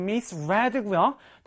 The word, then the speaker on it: misread: 여러분, 우리 read라는 단어는 현재, 과거, 과거 분사가 같은 형태인데 발음이 달라지죠? read. 다시 한번 read. 그래서 여기서도 과거형의 시제이기 때문에 misread로 읽어주시면 되겠습니다.